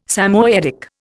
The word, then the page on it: Samoyedic: Indian